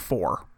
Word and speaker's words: Pfhor: I think it's just pronounced four. – The four? P-F-H-O-R?